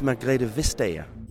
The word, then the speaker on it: Margrethe Vestager: How do you say it? – It's actually, it's Margrethe Vestager.